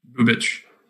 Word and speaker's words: Bubic: Bubik or Bubik? – Bubic. – Oh, he's an Eastern European? – Yeah, apparently. – That's how you would pronounce it if he was in the NBA.